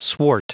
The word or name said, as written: swart